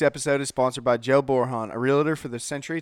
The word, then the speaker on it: Borjon: That's Joe, J-O-E, Borhan, B-O-R-J-O-N, yes, the J is silent, realtor.